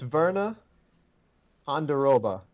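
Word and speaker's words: Virna Jandiroba: So it's Verna Honduroba, I believe. – That's pretty good.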